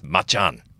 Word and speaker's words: Macan: Because we've been told it's Macan. – Macan.